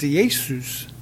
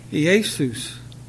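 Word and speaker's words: Iesous: Well, in Greek, it's pronounced Iesus. Iesus.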